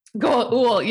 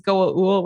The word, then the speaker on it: Goa'uld: Goa'uld, you're right. He's the only one who says Goa'uld without a D. – Goa'uld. – He has his own special pronunciation.